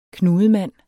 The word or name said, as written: knudemand